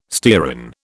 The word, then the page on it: stearin: British English